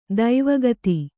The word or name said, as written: ದೈವಿಕತೆ